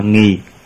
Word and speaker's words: nghì: Chữ nghĩ mình đọc là nghì, dùng từ đó chỉ rõ tác dụng của các thù thắng mà người ta có được.